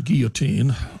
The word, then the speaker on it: guillotine: It gave rise to the Jacobins, gave rise to the Song of the Guillotine, or more appropriately pronounced, guillotine.